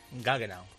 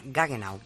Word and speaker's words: Gaggenau: esta no sé si se pronuncia bien, Galleno. – Gaggenau. – Ah, Gaggenau, vale.